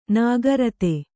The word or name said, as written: ನೇಕಾರಿತಿ